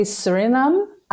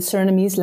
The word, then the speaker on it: Suriname: In English, you would say Suriname, I guess, but in the Surinamese language, which is also Dutch, it mixed between Dutch and Creole, it's Suriname.